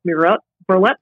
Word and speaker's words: murrelet: but they are the two finest marbled murrelet projects around.